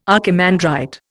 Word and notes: archimandrite: Indian

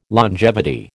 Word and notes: longevity: American English

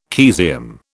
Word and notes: caesium: British English

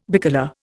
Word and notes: bicolour: Indian

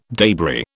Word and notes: debris: British English

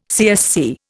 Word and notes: CSC: Indian

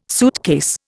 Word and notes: suitcase: Indian

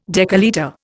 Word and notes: decalitre: Indian

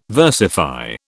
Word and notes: versify: British English

